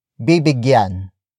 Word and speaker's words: bibigyan: It is the focus marker that introduces the main noun of the sentence, but in this case it's bibigyan. Bibigyan. Bibigyan. Bibigyan is spelled as B-I-B-I-G-Y-A-N.